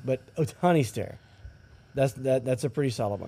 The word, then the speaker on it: Ohtani: Ohana Stare. Shohei Ohtani. – Yeah. – Did I say that right? Isn't that where it is? – Ohtani.